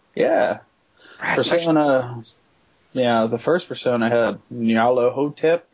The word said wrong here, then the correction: Nyarlathotep: or how do you say that? – What? You're thinking of Bubba Hotep, that movie about the mummy. – Whatever. Anyway. – Oh, no, no, you're right. Nyarlathotep. Oh, yeah, yeah. Nyarlathotep.